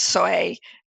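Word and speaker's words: Soay: And I have this contrast I have contrasting pictures in the book of, I think it's pronounced soy sheep, which is the closest to the sort of untampered with sheep that we have today.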